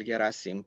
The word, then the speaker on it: Gherasim: So again, my name is Gabriel Gherasim, perfect pronunciation of the last name, by the way.